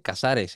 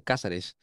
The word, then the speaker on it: Cázares: Pero cuando vas, es como cuando te dicen, no se dice Casares, se dice Cázares, y tú, ¿cómo, güey?